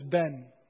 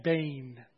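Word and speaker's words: bane (בֵּן): In the Hebrew, a lot of people describe, pronounce this ben, but it's actually bane in the Hebrew. Bane.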